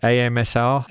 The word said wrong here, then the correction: ASMR: ASMR. – ASMR.